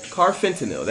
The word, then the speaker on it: carfentanil: It is from carfentanil. Carfentanil. That is how you say that.